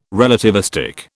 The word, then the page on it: relativistic: British English